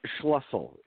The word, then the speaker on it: Schlissel: You probably said it more correctly than most, actually. That would be the German pronunciation. – Can you tell us how to say your last name? – Schlissel is the Americanized version.